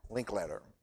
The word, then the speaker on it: Linkletter: It's Art Inkletter. Yeah, Linkletter.